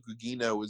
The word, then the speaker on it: Gugino: And Carla Gugino is in on it too, but Nick Cage turns her. – Just so you know, I looked it up, Gugino, which is my least favorite possible pronunciation of that.